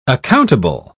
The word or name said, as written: accountable